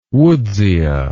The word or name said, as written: woodsia